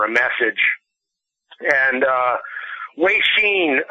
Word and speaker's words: Weixin: There's an app, and the correct pronunciation for that is Weixin. – Weixin, okay.